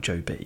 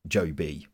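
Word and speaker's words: Jobe: Well, maybe, but It's not a two-syllabled name, is it? It's Job, not Joby.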